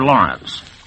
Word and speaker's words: Laurents: This evening's drama is Home of the Brave by Arthur Lawrence. The author pronounces his last name like Gertrude Lawrence or Lawrence of Arabia, but he spells it L-A-U-R-E-N-T-S.